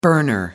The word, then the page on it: burner: American English